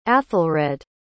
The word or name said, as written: aethelred